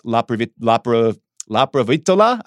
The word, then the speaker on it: Laprovittola: I'm probably going to butcher this, but I think it's Nicolas Laprovittola. I'm not perfect. – Sure I'm saying his name. – You did it.